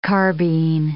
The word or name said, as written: carbine